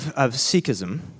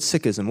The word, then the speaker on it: Sikhism: I guess to start, as we look at the topic of Sikhism, now, am I saying that right? Because I've heard different, sometimes we call it Sikhism or is it Sikhism? What's the proper pronouncement? – The official is Sikhism, but we're okay with Sikhism.